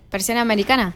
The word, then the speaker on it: Persiana americana: ¿Cómo se pronuncia Presión Americana? – Persiana americana. – Persiana americana, muy bien.